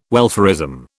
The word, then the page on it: welfarism: British English